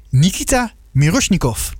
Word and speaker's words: Nikita Mirosnikov: Nikita Mirosnikov. – Ja. – Is dat een beetje aardig verwoord ook, Nikita? Heb ik dat goed uitgesproken? – Mooie uitspraak. Ja?